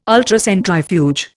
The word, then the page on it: Ultracentrifuge: Indian